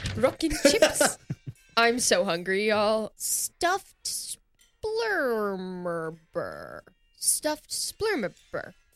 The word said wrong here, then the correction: splurmoober: I believe it's pronounced splurmoober.